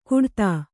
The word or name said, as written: ಕುಡತಾ